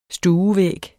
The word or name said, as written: stuevæg